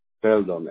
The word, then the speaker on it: Feldon: Is it S-O-L or? – S-O-L, yeah. – Okay, and then your last name. – Feldon, F-E-L-D-O-N.